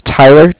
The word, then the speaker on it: Tyler: Name is Tyler, T-Y-L-E-R, and I'm with Bank of Cardiff. That's spelled C-A-R-D-I-F-F, as in Frank.